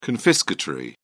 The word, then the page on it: confiscatory: British English